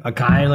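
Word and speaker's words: açaí: Guama Brewery, the acai or the acai, like we used to say back in the day before Google pronounced.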